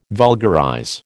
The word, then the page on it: vulgarize: American English